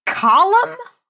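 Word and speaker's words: Colm: It's pronounced column? – I know, that's really, like...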